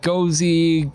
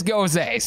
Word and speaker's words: Gose: I had to pronounce, I did not know if it was like Gozy, Goose, something like that. It is Gose, so I was able to get this one correct.